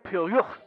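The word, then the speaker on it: Pierrot: It's pronounced Piero.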